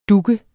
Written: dug

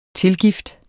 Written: tilgift

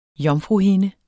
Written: jomfruhinde

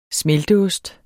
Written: smelteost

